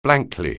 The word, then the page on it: blankly: anglais